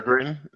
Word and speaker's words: Peregrine: Miss Peregrine? – Yeah.